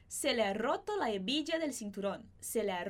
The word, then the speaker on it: hebilla: Então, esse H do A não vai ser pronunciado, nem o H de hebilla.